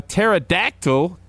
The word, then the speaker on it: pterodactyl: Why can't you hear a pterodactyl going to the bathroom? – I don't know. – I don't know. – Why? – Why can't you? – Because the P is silent.